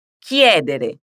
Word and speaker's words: chiedere: Com o verbo escolher, preste atenção na pronúncia do k em italiano. O encontro dessas duas consoantes com as vogais i ou e forma um som de k. Diga comigo, ki, ki. E agora, juntando a pronúncia de ki com a vogal tônica, que é, que é. Para sentir a diferença, vamos dizer as sílabas finais. Dere, dere. E adesso uniamo tutto. Chiedere. Perfetto.